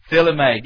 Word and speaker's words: philema: It's P-H-I-L-E-M-A, philema. Philema.